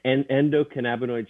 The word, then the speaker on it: Endocannabinoid: Endocannabinoid system.